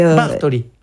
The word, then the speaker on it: Bartoli: Extrait de cet album Bartoli Vivaldi. Donc, moi je dis Cecilia, mais toi tu dis Cecilia. – Cecilia. – Bartoli. – Et, Bartoli. L'accent tonique est sur la.